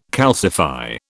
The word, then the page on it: calcify: British English